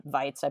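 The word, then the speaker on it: Waitz: But for the marathon, Greta Weitz was the Weitz, I believe is how she pronounces it, Greta Weitz.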